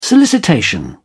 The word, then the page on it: solicitation: British English